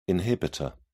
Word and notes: inhibitor: British English

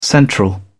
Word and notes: central: British English

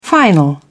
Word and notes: final: British English

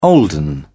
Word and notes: olden: British English

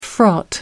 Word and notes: fraught: American English

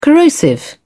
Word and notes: corrosive: British English

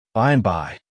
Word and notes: by and by: American English

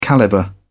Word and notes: calibre: British English

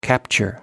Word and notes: capture: American English

